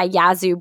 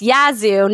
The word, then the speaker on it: Yazoo: I'm his co-host, and this podcast is brought to you by Yazoo Brewery. Yes, it's Yazoo, not Yazoo, because PK, it was brought to my understanding today that we've been mispronouncing that name of our sponsor.